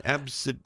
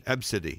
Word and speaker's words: Abcde: I think the mother got exactly what she deserved for naming her daughter, well, at least how you spell it, A-B-C-D-E, Redford. – How do you say that again? – Ab-City. – Ab-City. – And there's a dash in between ab and city.